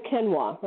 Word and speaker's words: quinoa: You had quinoa, right? – They probably had Jerusalem artichokes, too. – Yes, they had that, and they had Q-U-I, I never pronounce this right, Q-U-I-N-O-A, quinoa. – Quinoa.